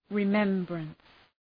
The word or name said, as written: remembrance